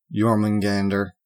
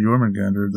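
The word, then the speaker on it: Jörmungandr: Can I just tell y'all that I love me some Jormungandr? Did I say it right? That's close.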